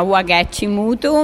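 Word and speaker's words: Wangechi Mutu: E abbiamo imparato a pronunciare il nome anche noi solo durante il progetto e abbiamo capito che si chiama Wagechi Mutu,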